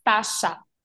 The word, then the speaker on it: Stasa: Um, Stisa Nikolic. it's Stasha.